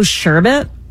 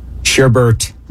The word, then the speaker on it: sherbet: Long Beach fruit cart and a mango sherbet. – Sherbet? – Sherbet? – Sherbet? Sherbert.